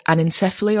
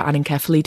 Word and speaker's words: anencephaly: and anencephaly or anencephaly, depending on how you want to pronounce it.